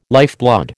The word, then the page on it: lifeblood: American English